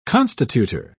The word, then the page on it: constitutor: British English